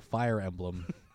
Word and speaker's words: Fire Emblem: Fern Blurton. – Oh, Fire Emblem. I guess that's one way to pronounce it. – Fern Blurton. – If a bit incorrectly, I guess.